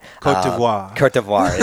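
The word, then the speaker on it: Côte d'Ivoire: Cote d'Ivory. I think I'm saying that right. – Cote d'Ivoire, yeah.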